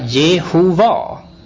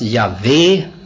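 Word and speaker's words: Jahve: Man tror nu att förmodligen ska det inte uttalas så, utan de vokaler som man har brukat för att komma fram till uttalet Jehova är hämtat från ett annat ord som man läste för att inte missbruka namnet. Man blev så vördnadsfull inför namnet att man sa inte, uttalade inte namnet, utan man sa istället Herren, eller man sa kort och gott bara namnet, eller ibland sa man bara Gud. Man har gissat att det ska uttalas Javé, men det är inte heller helt säkert.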